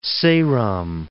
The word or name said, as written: Seram